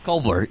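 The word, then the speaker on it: Kolber: I took the process one year, and the process was wowed by seeing Ron Rivera up close and Susie Kobler. Kobler. Yeah, is how you say her name.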